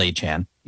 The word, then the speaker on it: Etienne: who is now called Achan. He's changed the pronunciation, to a massive contract, $50 million.